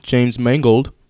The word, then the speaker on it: James Mangold: In an interview with THR, Logan director James Mangold, did I say that right? – Mm-hmm.